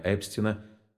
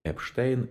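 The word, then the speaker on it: Epstein: Фамилия легендарного менеджера «The Beatles» Брайана Эпстина по-разному интерпретируется на русском языке. Эпштейн, Эпстайн. Известно, что Джон Леннон и другие битлы нередко произносили ее как Эпстайн. Однако переводчица приняла решение отображать ее как Эпстин. Данный вариант фонетически совпадает с произношением, которое, согласно примечанию в его автобиографии, предпочитал сам Брайан.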